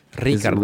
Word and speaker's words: Ricardo: Ricardo. If you're going to say in Spanish, you got to roll the R's.